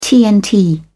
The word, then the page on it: tnt: British English